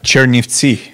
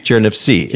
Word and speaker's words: Chernivtsi: I'm sure I'm mispronouncing the name of the city, but how would you pronounce where we're at? – Chernivtsi. – Chernivtsi.